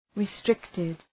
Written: restricted

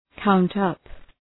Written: count up

